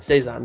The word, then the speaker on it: Saison: I think I'm saying that correctly. I never know how to say that. I think it's Saison. Saison? – Saison. Saison. There you go.